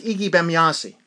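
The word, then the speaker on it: Ege Bamyasi: I spent so much time listening to Delay 68 in my life, but it's got to be is it Edge Bamyasi? I've never said it out loud. – Oh yeah, I've heard it pronounced Iggy Bamyasi.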